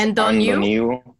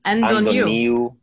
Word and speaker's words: Antoniou: But it is funny because if you said it right, you'd say Antoniou, which is the NT is almost like an ND, but the T is there. So it's Antoniou. – Antoniou.